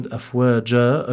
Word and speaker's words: أفواجا: And then with the end of the word afwaja, over here we have an alif, and the reason why the alif is pronounced is because we're stopping on this verse, and the tanween is not mentioned.